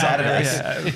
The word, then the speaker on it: Adidas: Well, it's only, only Americans say Adidas. It really is Adidas.